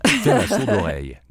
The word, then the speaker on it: faire la sourde oreille: No, I'm sorry, you didn't pronounce that right. – Faire la sourde oreille. – That's how you pronounce it.